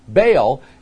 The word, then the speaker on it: Baal: There's another God out there, little g, that is pronounced Baal, and his name does mean Lord or the Lord.